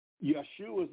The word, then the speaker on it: Yeshua: So even in your Bible, if you were reading it correctly, anything with the J sound should still be pronounced with the Y sound, not the J. So Yeshua's name, Yeshua or Yeshu, is the way that it would sound in the English.